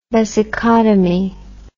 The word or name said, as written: vesicotomy